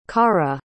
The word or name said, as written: carra